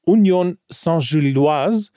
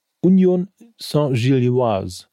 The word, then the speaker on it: Union Saint-Gilloise: Um 18.45 Uhr empfängt Union Berlin den belgischen Club Union Saint-Gilloise an der alten Försterei. Ich finde, das habe ich sehr gut ausgesprochen, findest du nicht? Mhm. Gilloise. Chapeau. – Mhm. Union Saint-Gilloise.